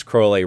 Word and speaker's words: Crowley: it's pronounced Crowley, rhymes with holy.